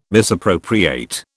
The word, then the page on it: misappropriate: British English